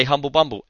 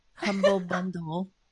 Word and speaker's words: Humble Bundle: How many people do you know that say Humble Bumble? Everyone says it. – Yeah, it's funny. – It's one of the things that really grates on me. It's like, I feel like going up, you say it right.